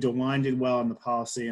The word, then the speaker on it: DeWine: The other one that I've heard rave reviews from my sister, who actually lives in Ohio, is Governor DeWine. Is that his name, Nick? Am I saying that right? – Yeah, that's the one.